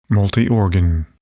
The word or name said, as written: multiorgan